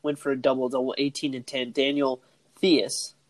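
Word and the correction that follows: Theis: these Tice, Tice, Tice. really? yeah, that's an interesting pronunciation when it comes to the way that that's spelled.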